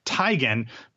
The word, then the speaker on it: Teigen: They John Legend and his wife, not Chrissy Teigen, she said on Twitter this week, it's actually pronounced Teigen, but she just stopped correcting people.